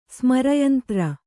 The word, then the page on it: ಸ್ಮರಯಂತ್ರ: kannada